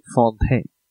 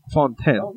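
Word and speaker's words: Fonteinen: So, the next beer we have in the 1 year anniversary episode 52 Ray Lewis Greg Oden episode is Dree Fontanet Lambic Brewerage Homage. Or as so kindly told me, they pronounce it Fontaine.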